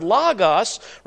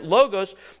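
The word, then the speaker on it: logos (λόγος): Then the word, of course, is the word logos, right? You've probably heard it logos, but it's pronounced in this particular place, logos, the word.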